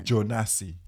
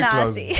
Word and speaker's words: Jonasi: Jonasi. – I was close. – Yeah, you're very Jonasi.